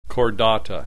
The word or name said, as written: Chordata